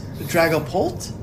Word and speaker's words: Dragapult: And fourth place was, I'm going to say it correctly, Scott, I promise, Dragapult. – Right? Yes, that was great.